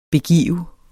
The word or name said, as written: BEGAV